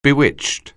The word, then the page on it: bewitched: anglais